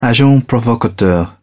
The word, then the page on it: agent provocateur: British English